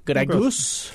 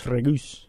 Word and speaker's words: Gregus: He's Slovakian, but Jan Gregus. Did I do that all right? Yeah, Gregus. Gregus.